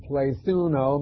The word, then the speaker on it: plethuno: P-L-E-T-H-U-N-O. Plethuno is the way it's pronounced, and that's the word multiplied.